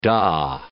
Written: dah

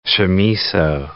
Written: chamiso

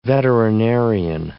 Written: veterinarian

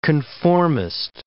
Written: conformist

